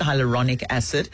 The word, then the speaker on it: hyaluronic acid: Back in studio with us to talk about, is it hyaluronic acid? – Oh, and that's exactly how you pronounce it, hyaluronic acid.